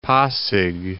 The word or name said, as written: Pasig